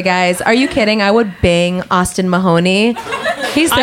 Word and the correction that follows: Mahone: Austin Mahone. – Mahoney. – Is it Mahoney? – Mahone. – I said it wrong. – I think then. – You're right, Mahone.